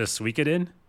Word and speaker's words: Suikoden: Similar to Suikoden, you know what I mean? And you're laughing because I said that correctly is why you're laughing.